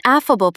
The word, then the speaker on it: affable: An affable personality puts everyone in the room at ease. Stress the first syllable. Aff-a-bull. Say it out loud. Affable.